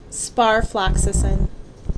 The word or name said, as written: Sparfloxacin